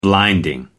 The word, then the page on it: blinding: American English